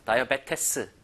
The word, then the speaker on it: diabetes: Diabetes. 자, 요거 읽어볼게요. 자, 스펠링과 실제 읽는 게 너무 달라요. Diabetes. 이게 아니라 Diabetes. Diabetes. 너무나 이상하게 읽히는 단어입니다. 실제 표기하고 너무 달라요. 읽는 게.